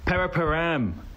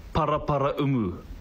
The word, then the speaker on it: Paraparaumu: I'll start. Paraparam. It's pronounced Paraparaumu.